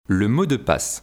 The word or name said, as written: le mot de passe